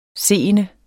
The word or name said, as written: SEENDES